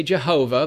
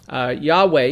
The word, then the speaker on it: Yahweh: but there's no J in Hebrew. It's a Yod. It's Yah. And so they say Jehovah, but that's a Germanic pronunciation. Yahweh is more correct here.